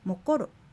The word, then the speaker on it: モコロ: モコロ、眠るという動詞ですが、アクセントはコロにあります。